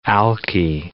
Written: alkie

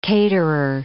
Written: caterer

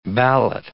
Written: ballot

